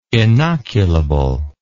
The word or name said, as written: inoculable